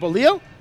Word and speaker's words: bolillo: Is it bolillo? – Yeah. Ah, I said it right.